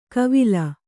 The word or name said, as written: ಕವಿಲ್